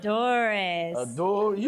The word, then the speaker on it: Cazadores: In this case, it's sipping on Cazadores, Tequila Cazadores. Am I saying it right? – Cazadores.